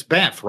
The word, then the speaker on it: Banff: It's Banff, right? That's the way it's pronounced? Banff? – You're bang on. Yeah, that's it.